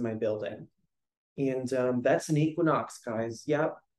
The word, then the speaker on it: Equinox: I recently learned that it's not pronounced ichone. I thought that that's just how fit people spoke, just very elegant and like French. So I learned it was Equinox, and I ended up becoming a member because we get a really sick discount.